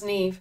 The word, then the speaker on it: Niamh: N-I-A-M-H? Yeah, I think it's pronounced Neve. Don't ask me why.